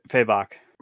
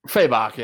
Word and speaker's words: Fabok: I'm just kind of a sucker for like big muscles and, you know, the David Finch, the Jim Lee's, the, I don't know how to pronounce his last name, Fabic. – Oh, Fabok. – Fabok, yeah.